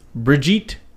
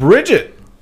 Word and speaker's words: Brigitte: The pronunciation is Brigitte Nielsen. – Yeah, you were wrong. – What do you mean? That's what I said. – You said Brigitte. – You guys said Bridget.